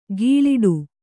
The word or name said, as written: ಘೀಳಿಡು